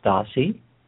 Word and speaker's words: Dorsey: His name is pronounced his name is pronounced Dossie. – Dossie.